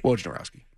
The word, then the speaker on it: Wojnarowski: It's just like the Wojnarowski guy. I'm not going to never say it right. – Wojnarowski.